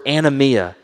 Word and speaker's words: anomia: He uses this word anomia, pronounced like anomias, like the restaurant down the road.